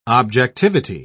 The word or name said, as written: objectivity